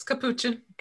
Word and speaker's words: Capuchin: No, it's Capuchin.